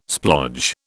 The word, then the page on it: splodge: British English